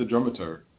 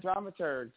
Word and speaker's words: dramaturg: What is a dramaturg? And I'm not sure I'm pronouncing it correctly. – Say, go ahead. – Yeah, you're doing it right, dramaturgs. Yeah, dramaturgy. Okay. It's the people who are doing the research and the development of the play.